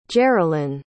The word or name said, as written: geralyn